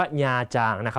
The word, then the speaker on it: ญาจาง: คำนี้ต้องอ่านให้ถูกนะฮะอ่านว่าญาจางนะ